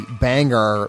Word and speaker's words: Bangar: By the Bangar label, that's B-A-N-G-A-R, rhymes with hangar, as in the airport thing.